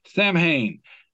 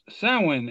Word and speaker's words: Samhain: It says, Samhain, a Gaelic word meaning summer's end. It is pronounced Samhain in Ireland, Samhain in Wales, 7 in Scotland, and pumpkin spice in America. – Yeah, baby.